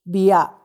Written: bia

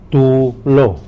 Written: tu lo

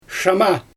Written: ʃaama

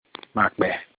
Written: makpe

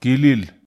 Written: kilil

